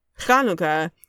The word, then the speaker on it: Chanukah: Yeah, so Hanukkah has the H sound at the beginning, and the H sound written in what I grew up calling Heblish, which is Hebrew Romanized, where it's written in the ABCs, basically, is CH. So you say, so if we want to traditionally pronounce Chanukah, it would be spelled CHA. Chanukah.